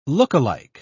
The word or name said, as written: lookalike